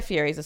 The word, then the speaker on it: Fieri: Guy Fieri's a celebrity, right? Fieri, I suppose, yes. – Is the preferred pronunciation.